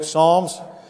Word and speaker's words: Psalms: You'll find this book, Psalms. Kind of looks like it's spelled Psalms, but the P is silent. Psalm 130.